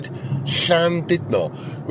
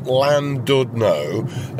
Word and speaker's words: Llandudno: Now, did you notice I said Llandudno, which is the Welsh pronunciation, not Llandudno, as a lot of people say.